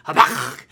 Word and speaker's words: Habakkuk: And Habakkuk is pronounced, if you have a cat, which I hope you don't, because cats, no one should have a cat. But if you did, and if you've ever seen a cat cough up a hairball, that's how you say his name. Habakkuk, that's how you say it.